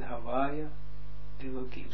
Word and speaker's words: Havaya Elokim: Hier staat het Havaya Elokim. Zo moeten we ook uitspreken.